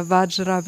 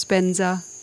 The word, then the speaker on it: vajra: And I also always have to remember the Tibetan Buddhists who chant mantras in Sanskrit and pronounce them so differently to Sanskrit. you know, like where Vajra becomes Benza, you know, which sounds quite different, at least to me.